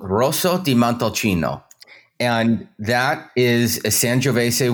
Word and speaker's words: Rosso di Montalcino: So it's Rosso, Rosso di Montalcino.